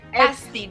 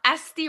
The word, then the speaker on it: Asti: I'm Asti Rappaport, like the letters S and T. Just saying the letters S-T together, Rappaport.